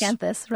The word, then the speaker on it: miscanthus: You also spoke with the director of the Center for Rural Affairs in Nebraska about, it's a word, I hope I'm pronouncing it correctly, miscanthus? – Miscanthus, right.